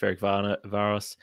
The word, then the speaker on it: Ferencváros: Ooh, nice pronunciation there. – Just rolled off the tongue. But look, Celtic did get a 2-0 victory against Ferencváros.